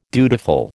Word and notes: dutiful: American English

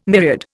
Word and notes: myriad: Indian

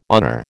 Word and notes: honor: American English

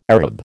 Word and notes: Arab: American English

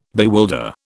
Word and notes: bewilder: British English

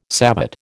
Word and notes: savate: American English

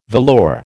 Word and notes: velour: American English

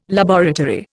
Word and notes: laboratory: Indian

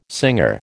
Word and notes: singer: American English

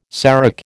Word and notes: serac: American English

